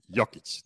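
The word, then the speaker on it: Jokić: Jokic.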